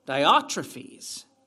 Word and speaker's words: Diotrephes: I want you to look at his name because it has a Greek pronunciation. Dioph, right? Trophies. Diotrephes.